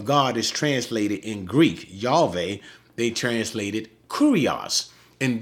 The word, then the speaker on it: Yahweh: Yod-Heh-Wow-Heh, Yahweh. Some people pronounce it Yahweh.